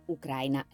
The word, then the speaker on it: Ucraina: Spesso mi avete chiesto appunto anche come si pronuncia, io di solito dico Ucraina paese, Ucraina l'aggettivo.